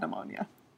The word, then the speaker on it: pneumonia: And it's like, oh God, now I've also got pneumonia. – All I know is that pneumonia's got a silent P at the start of it, like it's really cool.